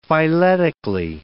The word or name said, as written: phyletically